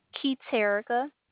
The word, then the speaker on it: Keterica: Keith Erica. – Erica? – Keith Erica. – Can you spell it? – K-E-T-E-R-I-C-A.